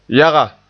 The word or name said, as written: yaga